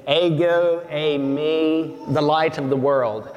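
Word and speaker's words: ego eimi (ἐγώ εἰμι): Iago Iami? Well, it's pronounced ego eimi.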